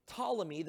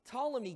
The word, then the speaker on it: Ptolemy: Ptolemy, the P is silent. Ptolemy gets Egypt.